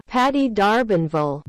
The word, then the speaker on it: Patty D'Arbanville: There was some speculation that much of the song was a message to Patty DeAm- the Armvanvel. Can't pronounce that. Patty D'Arbanville.